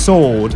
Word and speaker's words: sword: It's got a W in it, S-W, but it's pronounced as sword.